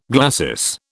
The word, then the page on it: glacis: British English